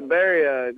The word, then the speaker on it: birria: And you have a chili chili bowl, a crispy tortillas, of course, chorizo, black bean, salsa, cheese, eggs, and then I'm not sure how you pronounce the beef bowl, the birria? – Yeah, birria.